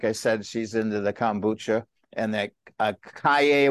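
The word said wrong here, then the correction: açaí: Acai bowls. – Acai bowl.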